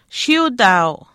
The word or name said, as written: shiiutaau